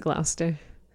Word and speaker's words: Gloucester: You pronounce it Gloucester. – Gloucester. – It looks like Gloucester, but it's Gloucester. – Gloucester.